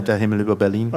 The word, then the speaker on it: Der Himmel über Berlin: Der Himmel über Berlin. – No? Der Himmel über Berlin.